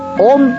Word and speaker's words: Om: Óigase bien, Om, que es O, y luego y después es la M de mamá,